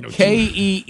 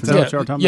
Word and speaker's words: Kente: It's spelled KENTE. – Mm-hmm. Is this kind of like looks like the scarf? – Kente?